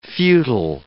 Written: futile

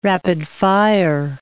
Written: rapid-fire

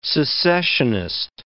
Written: secessionist

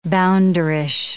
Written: bounderish

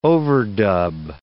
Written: overdub

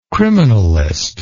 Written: criminalist

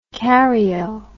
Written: karyo

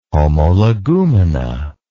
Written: homologumena

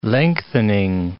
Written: lengthening

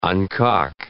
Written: uncock

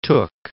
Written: Tooke

